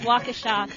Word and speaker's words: Waukesha: But it is Waukesha.